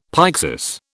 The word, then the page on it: pyxis: British English